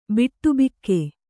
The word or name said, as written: ಬಾಡಬಾಕ